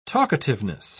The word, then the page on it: talkativeness: British English